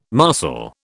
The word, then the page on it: marcel: British English